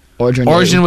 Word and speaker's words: Orgeron: Orgeron was apparently outraged, immediately quit. I don't know much what to make of that. I felt he deserved, he deserved the job. – Listen, Orgeron